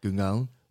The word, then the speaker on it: Gungahlin: Gungahlin.